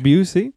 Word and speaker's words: Busey: Busey. – And I believe that's Gary Busey's son. – Okay. – I believe it's pronounced Jake Busey. – Busey? – It's not Busey. It's definitely not Busey.